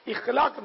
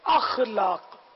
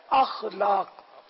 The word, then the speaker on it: اخلاق: اخلاق نہیں بلکہ اخلاق, صحیح تلفظ ہے اخلاق.